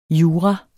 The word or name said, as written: jura